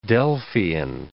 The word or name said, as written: Delphian